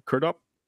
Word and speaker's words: Crudup: And was it Billy Crudup? – Crudup. – Is that how you pronounce them? – Mm-hmm. Yeah. Crudup.